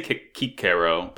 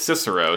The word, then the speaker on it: Cicero: But they say Kikero, but it's Cicero.